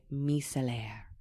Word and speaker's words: micellaire: So Anglophones would be correct to pronounce it micellar water. However, the same word is pronounced differently, and in my opinion, more beautifully, in French. So if you are Francophone, you would say micellaire.